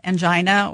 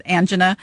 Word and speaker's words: angina: We call that angina, or some people will pronounce it angina.